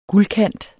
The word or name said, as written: guldkant